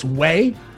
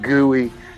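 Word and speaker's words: güey: It's way. It's a Spanish term like like dude or hey, what up, way? – I said gooey. Because you got it on there, G-U-E. – That's how it's spelled. I don't know if you know, it's in Spanish. And G's make a W sound.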